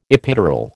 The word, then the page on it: epidural: American English